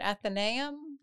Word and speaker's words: Athenaeum: I always mispronounce it. Is it Athenaeum? – Yep.